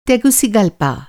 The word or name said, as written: टेगुसिगलपा